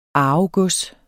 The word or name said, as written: arvegods